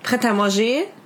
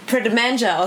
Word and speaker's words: Pret-à-Manger: Und eine Klassenkameradin von mir, damals hat das immer Pret-à-Manger ausgesprochen, anstatt Pret-à-Manger, weil das halt Französisch ist. Manger heißt ja Essen. – Yeah. – Auf Englisch ausgesprochen, das war immer.